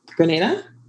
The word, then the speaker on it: Grenada: Grenada.